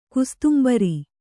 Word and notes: ಕುಸ್ತುಂಬರಿ: kustumbari

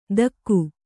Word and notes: ಥಕ್ಕು: Kannada

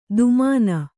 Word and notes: ದುಮಾನ: dumāna

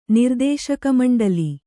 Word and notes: ನಿರ್ದೇಶಕಮಂಡಲಿ: kannada; nirdēśaka maṇḍali

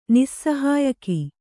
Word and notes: ನಿಸ್ಸಹಾಯಕ: nissahāyaka

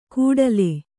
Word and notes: ಕೂಡಲೆ: kūḍale

